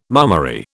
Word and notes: mummery: British English

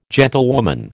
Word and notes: gentlewoman: American English

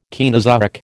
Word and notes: Caenozoic: American English

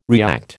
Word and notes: react: American English

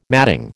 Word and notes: matting: American English

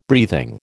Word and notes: breathing: American English

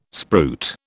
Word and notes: spruit: British English

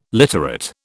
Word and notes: literate: British English

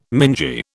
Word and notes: mingy: British English

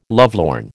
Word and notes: lovelorn: American English

